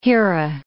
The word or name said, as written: Hera